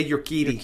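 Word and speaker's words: Urquidy: And I never know how to pronounce his name, even when they say it on TV. I forget. Yerquidi? – Jose Yerquidi.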